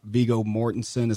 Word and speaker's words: Viggo Mortensen: Casey Affleck for Manchester by the Sea, Andrew Garfield, which is the Hacksaw Ridge, Ryan Gosling, which is La La Land, Viggo Mortensen, is that how you say his name? – Yes.